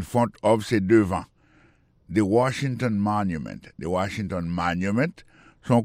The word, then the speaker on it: Washington: Si n'a mettez accent sur accent encore, on voit que mot Washington là, accent tombé sur premier syllabe là. Pour mot monument, il tombé sur premier syllabe là. The Washington Monument. Monument, c'est comme ça on dit monument en anglais.